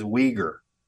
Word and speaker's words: Uyghur: the word is Uyghur.